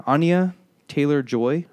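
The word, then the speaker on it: Anya Taylor-Joy: Anya Taylor Joy, I believe is how you say the actress's name.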